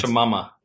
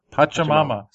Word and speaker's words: Pachamama: So Landslide, Roots, I'm not going to try to pronounce Pachamama. – Pachamama.